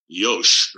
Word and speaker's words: Yoshi: And one of the things they taught us at Toyota is Yosh, right? Y-O-S-H-I is how it's spelled, but it's pronounced Yosh, and basically means check, right?